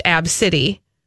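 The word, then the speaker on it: Abcde: Those letters, when put together in name fashion, reportedly are pronounced Ab City.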